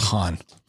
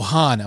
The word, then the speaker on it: Huan: His name is spelled H-U-A-N. – H-U-A-N, Juan. – Yes. That's what I thought. It's Han. – Han. – So I called to talk to him and I said, is Juan there? And they're like, who? And I said, I could be saying it wrong. H-U-A-N. They're like, oh, Han. I'm like, okay.